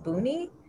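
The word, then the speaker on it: Buuni: It's called, I think it's pronounced Buni. It's B-U-U-N-I.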